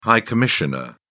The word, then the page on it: high commissioner: British English